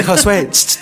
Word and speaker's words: Josué: Arroba Josué y en bajo corro. Daisy Ridley at J-O-S-U-E.